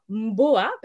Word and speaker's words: Mboa: Et en Douala, Mboa, parce que ça se prononce comme ça veut dire à la maison, chez soi.